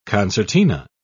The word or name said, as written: concertina